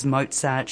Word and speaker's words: Mozart: I see there's Mozart, Schumann, Schubert.